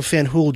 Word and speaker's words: Fanjul: Fanjul family, and that's spelled, by the way, F-A-N-J-U-L, if you're interested, if you want to Google these people or look any of this up, Fanjul.